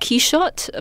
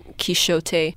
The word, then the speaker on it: Quichotte: It's pronounced Quixote, but you can also pronounce it Quixote.